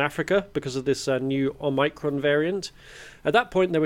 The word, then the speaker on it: Omicron: it's Omicron. The ancient Greeks did. The emphasis is on the first...